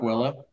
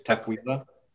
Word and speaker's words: Tukwila: It's not Tukwila. It's Tukwila.